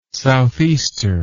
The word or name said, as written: southeaster